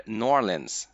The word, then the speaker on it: New Orleans: Jag tänkte att vi ska prata om eh, New Orleans, eller Norlins som eh, jag med mitt perfekta uttal eh, vet att de säger där nere i Louisiana.